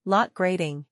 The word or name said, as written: Lot Grading